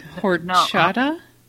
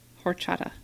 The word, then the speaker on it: Horchata: Horchata? Horchata.